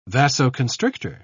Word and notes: vasoconstrictor: British English

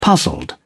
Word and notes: puzzled: British English

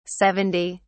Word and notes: 70: British English

